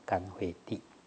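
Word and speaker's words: 乾慧地: 他有智慧，那没有禅定水，所以称为干，这个不能念作钱，干慧地。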